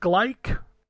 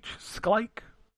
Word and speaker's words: Schleich: I think this is pronounced Sklike. S-C-H-L-E-I-C-H. Sklike.